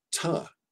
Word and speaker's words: to: Where we, you know, teach to, but we pronounce it ta with a schwa because it's unstressed.